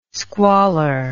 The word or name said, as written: squalor